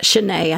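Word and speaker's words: Chene: Shane, Sheen Street. I'm not sure how to pronounce that. Shanae, I think it is. It's C H E N E if you're from Detroit, and I'm mispronouncing it.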